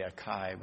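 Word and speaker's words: açaí: Spelled A-C-A-I. I want to say Acai, but it's pronounced Acai.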